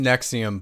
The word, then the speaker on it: NXIVM: It's pronounced Nexium, but it the acronym for it is NXIVM.